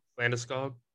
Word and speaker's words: Landeskog: I can't pronounce the L last name. – Landeskog? – Yeah.